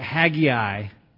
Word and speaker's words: Haggai: I'm going to pronounce it Haggai. That's the best pronunciation. It looks like it's two syllables, but we're going to throw in three just for fun.